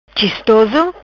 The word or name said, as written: Chistoso